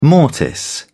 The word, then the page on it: mortise: British English